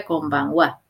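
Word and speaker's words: konbanwa (こんばんは): Y la wa se escribe con ja también, ¿no? Tengan cuidado. Pero se pronuncia konbanwa.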